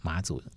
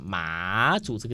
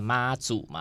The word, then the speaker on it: 马祖: 马祖真的可以去玩一玩。 – 对，那另外就是我们既然都提到马祖了。应该就要讲一下马祖这个地名是怎么来的。 – 嗯，怎么来的？ – 马祖刚刚有讲说，就是叫大家不要念错，不要念成妈祖嘛，对不对？但是其实马祖这个地名跟妈祖真的是有一点关系的。